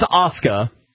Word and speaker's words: Asuka: Is it Asuka? – Asuke. – Okay. – It's pronounced Asuka.